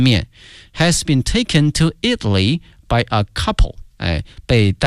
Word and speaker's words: Italy: 意大利这个词啊，同学们要小心了，它的名词和它的实用词呢，这个发音同学们一定要小心了。意大利呢是 Italy 啊，国名。如果说意大利的或意大利人叫 Italian，哎，所以说这个发音还是有区别的。 Italy Italian by a couple who opened a restaurant in Florence, the dish has become very popular 啊。